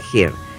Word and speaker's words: Her: Búsquenla, se escribe H-E-R, pero se pronuncia Here, la película.